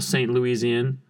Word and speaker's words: St. Louisian: I don't feel bad being a St. Louisian. That's how you pronounce that.